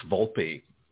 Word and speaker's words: Volpe: It's Volpe.